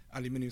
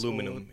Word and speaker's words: aluminum: It might look at things like aluminium suppliers or steel suppliers. – Aluminum in this country is how we pronounce it.